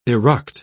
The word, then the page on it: eruct: British English